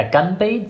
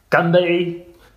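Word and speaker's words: Ganbei (干杯): Ja, Gambay sagt man immer, wenn man anstößt. Das hast du richtig äh, ausgesprochen. Aber als ein richtiger Nordchinese, sagt man. Ganbei, Gan, nicht Ganbei, ah, okay.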